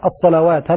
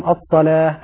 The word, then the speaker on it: الصلاة: قلنا كلمة الصلاة رسمت بواو في كل المصحف، ومن الأخطاء الشائعة أن تقرأ الصلوات، هذا خطأ. الصلاة يعني الواو هذه لا تنطق، المنطوق ألف بعد اللام، المنطوق الألف التي بعد اللام، الصلاة هكذا. أيضا في قوله تعالى وأني فضلتكم، اللام رققة، بعض الناس ينطق اللام مفخمة يقول فضلتكم، هذا خطأ، هذا من اللحن الخفي، اللام رققة.